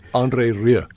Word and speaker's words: André Rieu: André Rieu.